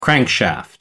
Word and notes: crankshaft: American English

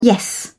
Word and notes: yes: British English

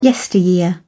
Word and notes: yesteryear: British English

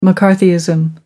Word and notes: mccarthyism: American English

